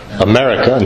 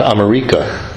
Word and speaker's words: America: Nagarjuna, Madhyamika, not Madhyamika, America, not Amerika. That's the example I always use to demonstrate it's not Amerika, it's America. Two short syllables at the end.